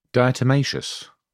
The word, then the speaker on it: diatomaceous: Diatomaceous.